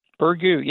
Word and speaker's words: Burgoo: Burgoo, yep, that's correct.